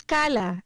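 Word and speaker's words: Khala: Esta es Cala, se escribe K-H-A-L-A y se pronuncia Cala.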